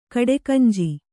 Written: ಕಡೆಕಂಜಿ

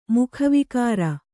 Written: ಮುಖವಿಕಾರ

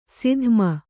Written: ಸದುಮ

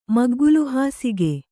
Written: ಮಗ್ಗಲುಹಾಸಿಗೆ

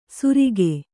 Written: ಸುರಿಗೆ

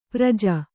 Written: ಪರಿಜು

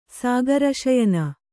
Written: ಸಾಗರಶಯನ

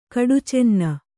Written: ಕಡುಚೆನ್ನೆ

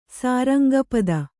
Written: ಸಾರಂಗಪದ

